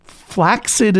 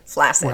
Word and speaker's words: flaccid: Flaccid is an accepted pronunciation.